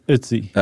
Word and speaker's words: Ötzi: Yes, it's Ötzi. – Okay. – It rhymes with Tootsie, I saw someone put it. I think our good friends at Smithsonian Magazine. – Yeah, Ötzi. There's a bit of an R in there.